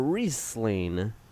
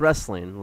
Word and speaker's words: Riesling: Yes, or Riesling, you know? Not yeah. pronounced wrestling like I once thought.